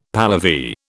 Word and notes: Pahlavi: British English

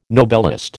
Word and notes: Nobelist: American English

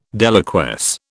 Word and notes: deliquesce: British English

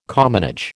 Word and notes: commonage: American English

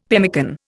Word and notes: pemmican: Indian

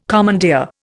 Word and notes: commandeer: Indian

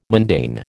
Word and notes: mundane: American English

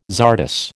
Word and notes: czardas: American English

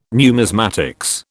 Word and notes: numismatics: British English

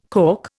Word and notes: calk: Indian

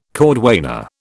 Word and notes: cordwainer: British English

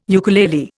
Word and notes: ukulele: Indian

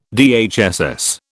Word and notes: DHSS: British English

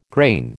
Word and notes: grain: American English

